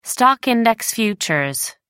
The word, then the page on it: stock-index futures: American English